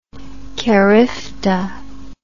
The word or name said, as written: CARIFTA